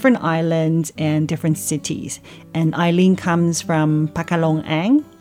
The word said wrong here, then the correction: Pekalongan: did I pronounce it correctly? Pekalongan. Pakalongang. Okay.